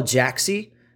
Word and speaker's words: Jaaxy: So the tool that I recommend for for doing market research is called Jaaxy. Uh, at least I that's how I pronounce it. It's J A A X Y uh, dot com, and it's a great.